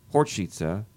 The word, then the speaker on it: Horcica: Well, the name is pronounced as Horcica, and that is a Czech name.